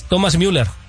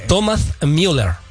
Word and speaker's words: Thomas Müller: Rápidamente, es Thomas Müller. Ah, lo pronuncié bien, discúlpame, Aguayo. Thomas Müller.